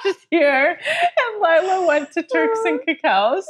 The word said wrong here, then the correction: Caicos: Is that how you say it? – Caicos? – Not, it's not chocolate.